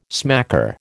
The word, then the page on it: smacker: American English